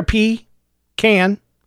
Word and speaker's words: pecan: Crick is C-R-I-C-K. Creek is C-R-E-E-K. The big one here, pecan. Pecan is the way to say pecan. P-E-C-A-N. It's right there. P-can. Some people say pecan. That would be P-E-C-O-N.